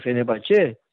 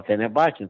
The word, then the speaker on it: Fenerbahçe: Quando ele vai dirigir o Fenerbahçe, que é a pronúncia co coerente seria essa, correta, mas aqui se fala Fenerbahçe,